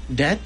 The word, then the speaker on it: debt: Debt? – That's how you pronounce D-E-B-T.